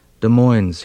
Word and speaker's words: Des Moines: It was a problem which had dogged me for a few years, this confusion of places, and when in fifth grade geography I had pronounced Des Moines as though it were a village in France, Mr. Kephart led me to the map on the front wall, and so I'd know where I was, pressed my forehead squarely against Iowa. Des Moines, he'd said, rhymes with coins.